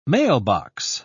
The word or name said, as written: mailbox